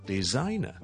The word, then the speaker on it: designer: Number two, when you say designer, the S in the middle is actually pronounced as a Z. It's design, 是一个 Z 音来的，中间的 S, z, z, z, so it's designer, designer, so graphic designer, graphic designer, graphic designer.